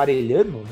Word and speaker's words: Arellano: Mas enfim, falando de Davi Arellano, ou Arellano, né, como seria a pronúncia hispânica, ele ele foi um dos ele é considerado até hoje um dos, prim, dos maiores jogadores da história do Colo-Colo.